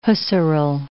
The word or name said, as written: Husserl